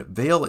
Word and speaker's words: M(h)aol: And M(h)aol is spelled M H A O L. It's a uh, Gaelic, um, but spelled with an spelled differently from how you say it.